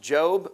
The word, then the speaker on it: Job: And once again, the name is pronounced Job, but it's spelled like Job.